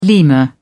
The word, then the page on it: lemur: British English